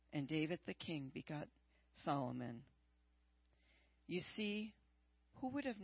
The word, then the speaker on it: Solomon: And Solomon begot, it's not, it's Solomon, the L is silent.